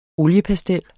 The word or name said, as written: OLIEPASTEL